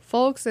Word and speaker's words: Fowlkes: Folks is F-O-W-L-K-E-S.